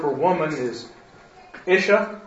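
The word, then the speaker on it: Isha (אשה): Yeah, but the accent's on the first syllable. – Isha, Isha.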